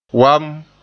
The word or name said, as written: wam